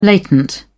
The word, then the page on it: latent: British English